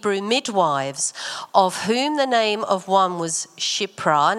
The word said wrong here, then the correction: Shiphrah: Now it's actually pronounced Shiphrah.